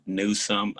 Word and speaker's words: Newsome: So I just refer to my name as Quan, but my name is Jaquan Newsom. You spell that J-A-Q-U-A-N. And then Newsom is like a compound word, new and some, N-E-W-S-O-M-E.